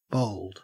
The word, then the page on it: bowled: Australian English